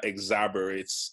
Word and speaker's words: exacerbate: But there have been tensions based on both of those two things, and um, this further exacerbates, exacerbates. How do you pronounce that word? – Exar- exacerbate. Exacerbate. – Exacerbates. Yes.